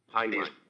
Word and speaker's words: Heinlein: Robert Heinlein.